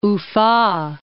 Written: Ufa

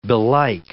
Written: belike

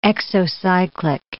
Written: exocyclic

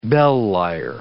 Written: bell lyre

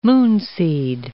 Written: moonseed

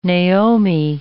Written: Naomi

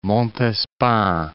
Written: Montespan

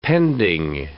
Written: pending

